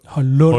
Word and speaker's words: Højlund: Men de har lige glemt, at han stades med Ø, selvom han ellers meget udtrykkeligt har fortalt, hvordan Højlund... – Højlund. Høj udtales.